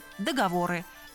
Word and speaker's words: договор: Тренеры, редакторы, кремы, договоры. Это литературная норма. Заодно вспомним, что в слове «договор» ударение падает на последний слог.